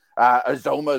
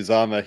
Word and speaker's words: Uzomah: Azoma is going crazy. I always pronounce his name wrong.